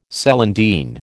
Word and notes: celandine: American English

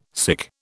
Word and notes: sic: British English

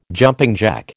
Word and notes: jumping jack: American English

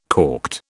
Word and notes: corked: British English

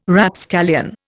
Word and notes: Rapscallion: Indian

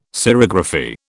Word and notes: serigraphy: British English

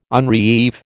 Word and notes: unreeve: American English

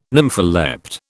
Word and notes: nympholept: British English

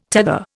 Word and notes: tether: Indian

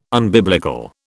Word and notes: unbiblical: British English

